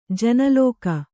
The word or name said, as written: ಜನಲೋಕ